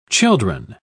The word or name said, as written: children